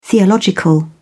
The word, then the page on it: theological: British English